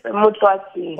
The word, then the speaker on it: Moutloatse: Moutloatse.